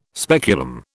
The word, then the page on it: speculum: British English